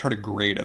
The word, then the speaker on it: tardigrade: As BJ just pointed out, it's tardigrade. I think is how you actually pronounce it.